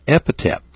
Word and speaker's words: epithet: It's an epithet of Jesus Christ. What? What? It's an epithet, E-P-I-T-H-E-T. Epithet. That's the way you pronounce it.